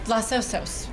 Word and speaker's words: Los Osos: How do you pronounce it? O-S-O-S Vineyard. – Los Osos, which means the bears in Spanish.